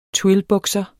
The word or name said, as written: twillbukser